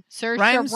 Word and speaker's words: Saoirse: Saoirse Ronan. – Rhymes with inertia.